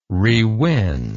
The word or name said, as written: rewin